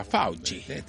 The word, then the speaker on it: Fauci: Así se pronuncia Fauci.